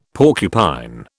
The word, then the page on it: porcupine: British English